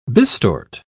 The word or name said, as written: bistort